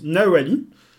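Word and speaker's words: Nahualli: Chez les Aztèques, dans leur langue, ça se prononce Nahuali.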